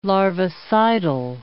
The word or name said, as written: larvicidal